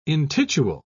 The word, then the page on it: intitule: British English